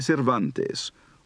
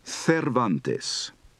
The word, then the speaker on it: Cervantes: The opening sentence of Chapter 1 of Don Quixote de la Mancha by Miguel de Cervantes, or in Castilian pronunciation, Cervantes.